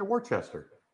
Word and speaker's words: Worcester: Yeah, Worcester.